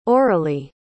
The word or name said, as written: oralee